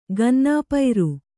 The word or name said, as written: ಕನ್ನಪುರ